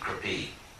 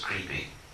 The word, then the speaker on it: Kripi: Drona married a wonderful lady named Kripi, sometimes mispronounced in the West as Creepy, but actually her name, you may have heard that, you know, West people say like her brother Kripa and Creepy, and so, but actually her name is Kripi.